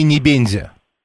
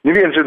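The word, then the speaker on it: Небензя: Я говорю, правильное ударение в его фамилии Небензи. – Небензи, да.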